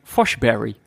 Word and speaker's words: Forsberg: En er stond bij Forsberg, stond er als als fonetische omschrijving Foshberry.